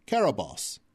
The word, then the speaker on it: Cerberus: Or Carabos. – Is that the original Greek pronunciation? – It's not the original Greek pronunciation, but it is the anglicized pronunciation of the original Greek spelling.